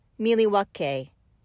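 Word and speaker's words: Milwaukee: So the question is titled, actually, it's pronounced Miliwake, which is Algonquin for the good land.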